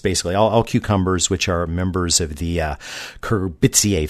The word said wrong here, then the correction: Cucurbitaceae: family. I left a syllable out. Cucurbitaceae family.